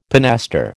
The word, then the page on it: pinaster: American English